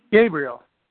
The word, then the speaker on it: Gabriel: Hello, I'm Gabriel from New Jersey. – Hi there. – Hello. – What did you say your name is? – Gabriel. – Gabriel. Hi, Gabriel. – Like the angel, yeah.